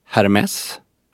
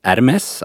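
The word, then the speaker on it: Hermès: Någon som inte har en aning om hur det uttalas skulle säga Hermes. Sen vad jag har förstått så uttalas det Hermes, alltså du lägger bort H, men du ska definitivt uttala S. Och vissa säger Hermé. Och där tycker jag att Hermé är det helt felaktiga.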